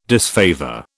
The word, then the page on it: disfavour: British English